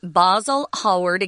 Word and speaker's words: Basil Hallward: Um, Oscar Wilde was Irish, but anyone in the UK in general, even today, they, they pronounce this word basil, and we say basil, which is an herb that you cook with. But we're going to say Basil Hallward again.